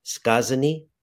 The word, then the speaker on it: Skazani: Prawdopodobnie źle wymówię polski tytuł. Skazanie? Dobrze mówię? Tak to się wymawia? – Skazani? Is that right? – Dobrze, nawet bardzo dobrze. Skazani.